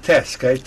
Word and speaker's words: TASC: And it's pronounced TASK, T-A-S-C. – Yes.